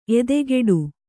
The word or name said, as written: ಎದೆಗೂಡು